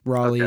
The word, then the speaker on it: Raleigh: Like Raleigh, okay.